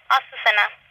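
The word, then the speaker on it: Azucena: Asusena.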